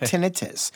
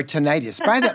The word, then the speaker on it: tinnitus: Pronunciation, there are two different pronunciations, and they're both correct. I mean, tinnitus is used by some people. Tinnitus are used by others, but the Brits say tinnitus. – Of course. – And the of course, right. That's right. As you know, those Brits. – Those Brits. – Anyway, that's right. But it's pronounced both ways.